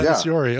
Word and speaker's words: Tatasciore: Tatasciore? – That guy's on hundreds of Blizzard games. That dude's all over everything. – Yeah. Tatasciore.